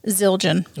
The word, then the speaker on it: Zildjian: Zildjian. – Ooh, that is. I've never heard that. – It looks crazy because it has a D, J, and then I, A, N. – Okay. – But Zildjian means symbol smith son.